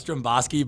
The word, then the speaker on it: Dromboski: Am I pronouncing that right? – It's Dromboski, but everyone calls you Sean.